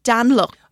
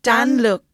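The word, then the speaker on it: Danyluk: And one of them is Anton Daly. – Dan Luck. – Oh, Dan Luck. Dan Luck. Okay. – Yes, I had to Google the pronunciation.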